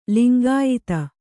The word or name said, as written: ಲಿಂಗಾಯಿತ